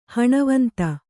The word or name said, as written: ಹಣವಂತ